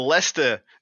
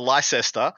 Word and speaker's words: Leicester: First beautiful event for you guys is the Leicester GT, as I'm heard it's called. It's not the Leicester, as it would be phonetically. – Oh, you did it. You actually did it right.